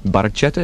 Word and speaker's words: Barchetta: Barchetta is that's how it's pronounced officially.